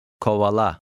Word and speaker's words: Kovala: The stress is on the last syllable. – Kovala.